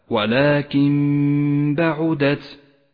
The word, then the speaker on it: ولكن بعدت: ولكن بعدت جاءت النون الساكنة وبعدها حرف الباء، والواجب أن نسقط النون من اللفظ، ولذلك هي رمادية اللون في مصحف التجويد إشارة إلى عدم نطقها، ونقف بغنة على حرف الميم إشارة إلى موقع الغنة، ولذلك لونت الميم بالأخضر. اقرأها هكذا ولكن بعدت.